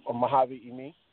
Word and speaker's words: Mojavi Emi: Emi, Mojavi Emi.